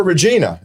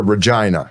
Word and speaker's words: Regina: So anyways, I'm in this, and I said, I'm going to Regina. And they said, no, you're going to Regina. – Yes. – And I said, is that how you pronounce it? Yeah, you pronounce it Regina.